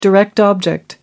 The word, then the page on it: direct object: American English